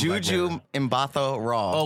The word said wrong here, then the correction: Gugu: Oh, Gugu. – Gugu Mbatha Raw. – You know anybody's name? – I don't know how it's pronounced. – It's Gugu.